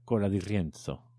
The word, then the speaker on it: Cola di Rienzo: El Renacimiento empieza con Cola di Rienzo casi un siglo antes. Lo voy a pronunciar otra vez porque la Z me ha salido súper italiana. Cola di Rienzo.